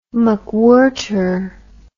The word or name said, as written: McWhirter